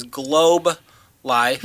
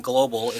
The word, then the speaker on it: Globe Life: This is Globe Life. Mm-hmm. I kept saying global. It's Globe Life mm-hmm.